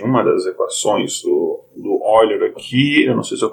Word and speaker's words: Euler: É, Euler é como a gente pronunciaria, a pronúncia em português, mas, é, enfim, a pronúncia da língua materna dele, da língua do Euler, a gente pronuncia Euler, né? Porque esse E, U tem som de OI, tipo Deutschland, né?